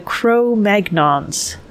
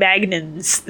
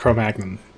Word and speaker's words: Cro-Magnon: that's a French word that I've been saying Magnans this whole time. – Well, that's how I've always, always heard it pronounced Cro-Magnon.